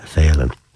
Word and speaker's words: Phelan: And as the rural Laois people, they don't usually pronounce the name Phelan, it's Phelan.